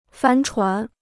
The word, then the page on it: 帆船: fān chuán